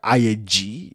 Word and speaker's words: Ayeji: Dr. Abayomi Ayeji. – Yoruba. – I'm assuming I'm pronouncing the name right. – You actually did it right. Ayeji. You did it right.